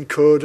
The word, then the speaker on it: could: We've got letters L that we don't pronounce in could and walk and salmon.